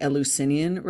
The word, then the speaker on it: Eleusinian: In this, I know it's a weird word, but it's actually pronounced the Eleusinian rites.